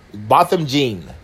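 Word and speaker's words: Botham Jean: Is it Botham? Gene, Botham Gene, that's his name.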